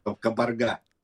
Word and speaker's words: кабарга: Скажите, пожалуйста, почему вдруг решили заговорить о кабарге? Правильно я ударение ставлю? – Точно, кабарга.